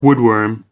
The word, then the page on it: woodworm: American English